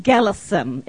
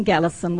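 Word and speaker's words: Kalasin: We live in Gallison. It has a K, but it's pronounced with a G. We live in Gallison, which is the northeast of Thailand.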